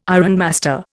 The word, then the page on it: ironmaster: Indian